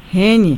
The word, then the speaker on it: Rennes: Rennes.